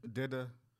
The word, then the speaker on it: DITA: Dida. I'm saying it right. D-I-T-A.